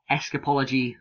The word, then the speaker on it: escapology: Zach, obviously, with a very technical escapology style. Yes, CWC commentators, that is how you say that word.